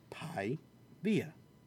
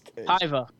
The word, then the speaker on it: Paiva: It's not Via. But it's P-A-I-V-A. – I-V-A. – P-A-I-V-A. Pi-via. – Yeah. So I'm gonna go with him by decision. Fuck you. Okay. Piva.